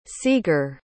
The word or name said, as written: sieger